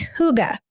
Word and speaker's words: hygge: We looked up the pronunciation and she has it in her book, but it's hygge.